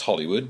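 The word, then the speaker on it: Holywood: That's Hollywood with one L. It's pronounced Hollywood, but the entertainment capital of the world and golf champion Rory McIlroy's hometown are very different places.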